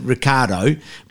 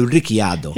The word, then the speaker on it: Ricciardo: But anyway, how you pronounce it, in Australia we pronounce it Ricciardo, but it's not. It's actually Ricciardo.